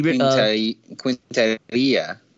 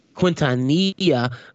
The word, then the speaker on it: Quintanilla: I don't know how to pronounce that last name. She Quintanilla. – Quintanilla.